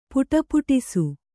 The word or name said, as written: ಪುಟುಪುಟಿಸು